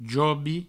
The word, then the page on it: jobi: Provençal